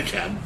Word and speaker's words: DeKalb: Oh, because there's like a DeKalb County. – Because it's not everywhere. DeKalb, but we call it DeKalb. Yeah. We live here, so are we right? – It's just like the Georgia City, like, C-A-I-R-O, Cairo.